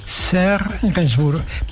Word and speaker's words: Serge Gainsbourg: ¿Lo he pronunciado bien? Serge Gainsbourg, perfecto.